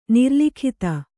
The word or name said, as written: ನಿರ್ಲಿಖಿತ